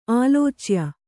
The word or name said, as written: ಆಲೋಚ್ಯ